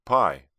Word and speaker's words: pi: Most of us, if we hearken back far enough to our school days, remember something about the number pi, pronounced pi, P-I-E, and symbolized by the Greek letter for pi.